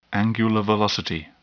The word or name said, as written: angular velocity